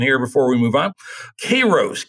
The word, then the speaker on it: Kairos: I've always heard it pronounced Kairos, but... – Kairos, okay.